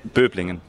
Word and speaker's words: Böblingen: Böblingen. – Yeah, it's quite close. – Yeah. – How do you say? – Böblingen.